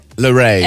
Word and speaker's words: Larrey: L'arrêt, mais ça s'écrit pas comme l'arrêt. – Larrey. L-A-R-R-E-Y.